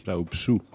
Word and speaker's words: Rahobisoa: So the right way to pronounce it over there would be Tantely Rahobisoa.